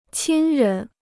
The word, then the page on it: 亲人: qīn rén